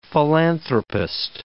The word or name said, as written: philanthropist